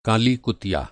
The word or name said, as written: कुतिया